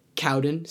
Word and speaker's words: Cowden: B-R-E-N-D-A-N, a period, Cowden, C-O-W-D-E-N, and then the number 2.